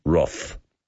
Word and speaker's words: Wrath: And Jan, bless her heart and soul, has just sent a text through, boys, it's pronounced Roth. – Grapes of Wrath.